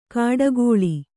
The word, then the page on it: ಕಾಡಗೋಳಿ: kāḍagōḷi